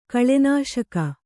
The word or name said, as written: ಕಳೆನಾಶಕ